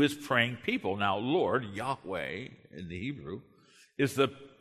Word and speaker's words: Yahweh: The Hebrew is the tetragrammaton. Uh, we pronounce it as Yahweh.